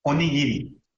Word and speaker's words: Onigiri: And they're called onigiri. Onigiri, that's how you say it.